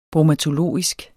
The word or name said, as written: bromatologisk